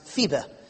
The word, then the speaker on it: Phoebe: Now, some pronounce it in English, Phoebe, but it's actually pronounced Phoebe.